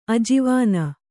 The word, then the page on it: ಅಜವಾನ: ajavāna